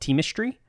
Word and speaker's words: Teamistry: you act as the producer of this podcast called Teamistry, produced for Atlassian. Did I pronounce that correctly? Yeah. – Yep, that's right.